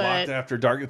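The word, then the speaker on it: Loc'ed After Dark: I don't know if it's supposed to be Loked or Locked. I don't understand. But Locked After Dark.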